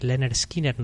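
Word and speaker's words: Lynyrd Skynyrd: Ellos publicaban su primer disco en el año 73, informando a todo el mundo cómo se pronunciaba su nombre, Pronounced Lennon Skinner.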